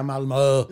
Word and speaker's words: Malmö: Fick vi lära oss i tisdags att man lärde sig uttala Malmö, så det har vi tränat på nu. – Nu ja. kan vi säga Malmö.